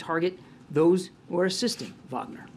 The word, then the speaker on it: Wagner: It's pronounced Wagner.